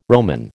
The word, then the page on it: Roman: American English